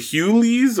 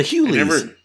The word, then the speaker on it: Hughley: it's The Hughleys or The Hughleys? – Oh, yeah, The Hughleys.